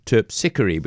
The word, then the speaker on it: Terpsichore: Is it Terpsichore, or Terpsichore? And the consensus was very strongly, it's Terpsichore, which sounds a little about something different, doesn't it? Anyway, that's the ballet music, Terpsichore, by Handel, the English Baroque soloist under Sir John Eliot Gardner.